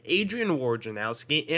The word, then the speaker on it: Adrian Wojnarowski: And for you guys who don't know, Adrian Wojnarowski and Shams Charmania, that's how you say it,